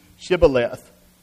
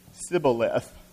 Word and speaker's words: Shibboleth: When he said, 'No,' they said to him, 'Then say, Shibboleth.' And he said, 'Sibboleth,' for he could not pronounce it right.